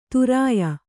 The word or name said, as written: ಧೌರೇಯ